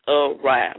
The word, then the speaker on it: awry: Not the incorrect pronunciation, aw, like A-W-W hyphen R-E-E, R-E-E. It's the correct pronunciation is awry. I'm talking about awry, A-W-R-Y.